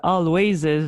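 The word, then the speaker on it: Alvvays: En fait, ils l'écrivent avec deux V, mais on le prononce Always.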